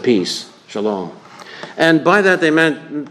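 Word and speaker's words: shalom (שלום): Well, the Hebrew word shalom. The Hebrew pronunciation would be shalom.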